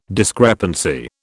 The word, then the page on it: discrepancy: British English